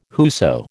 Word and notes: whoso: American English

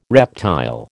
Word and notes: reptile: American English

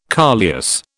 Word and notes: coleus: British English

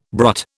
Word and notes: brut: British English

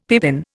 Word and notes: pippin: Indian

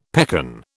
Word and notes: pekan: British English

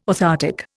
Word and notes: orthoptic: Indian

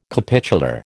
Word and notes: capitular: American English

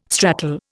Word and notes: stratal: Indian

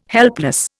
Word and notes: helpless: Indian

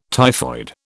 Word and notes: typhoid: British English

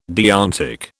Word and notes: deontic: British English

